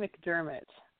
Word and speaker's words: McDermott: Shannon McDermott. – McDermott, with E-R.